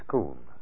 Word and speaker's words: Scone: As you go along the river, at one end of it, there's a little village of Scone. doesn't look like spoon when it's spelled, but that's what the local citizens call it.